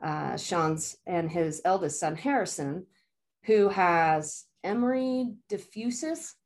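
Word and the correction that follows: Dreifuss: Did I say that right, Sean? – Dreyfus. That's close. It's hard.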